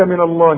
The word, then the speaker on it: من الله: النون بتتخن معاك ليه ما هو اصل لفظ الجلاله مفخم فاحنا مش عايزين نفخم الغنه ديت النون دي مش عايزين نفخمها ما نقولش من